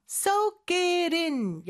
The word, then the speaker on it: Soak it in: Soak it in. 여기 연음되는 거 주의해 주시면 됩니다.